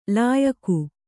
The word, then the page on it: ಲಾಯಖು: Kannada